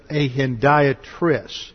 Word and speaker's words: hendiatris: And then we have an interesting phraseology here, known as a hendiatris. Hendiatris, that's spelled H-E-N-D-I-A-T-R-I-S.